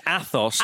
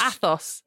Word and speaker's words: Athos: Is Athos? Just help me out. – I have repeatedly. – I know. – It's Athos. – Athos.